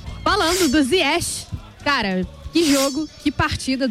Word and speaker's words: Ziyech: A gente tem a pronúncia Ziyech, marroquina. – Na verdade. – Ziyech, como o nosso amigo Brandão me falou ontem, que eu demorei Ziyech. Um século para conseguir. – É Ziyech, então. – Ziyech. É, a pronúncia marroquina.